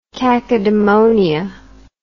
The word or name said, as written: cacodemonia